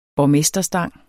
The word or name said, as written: borgmesterstang